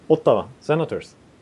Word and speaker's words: Ottawa Senators: Ottawa Senators.